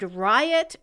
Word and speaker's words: Riot: Not a fan of the name Riot, even if it is pronounced Rio, like French. It is going to be pronounced Riot all the time.